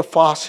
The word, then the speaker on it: Phos: And Fos is spelled P-H-O-S, Fos Hilaron.